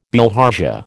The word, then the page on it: bilharzia: American English